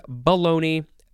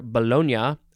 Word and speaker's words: baloney: B-A-L-O-N-E-Y. It is the first form. It is a variation of the word. You can pronounce this how you want to. I'm assuming both are correct. Baloney or Bologna. Bologna. Lots of ways to say that word.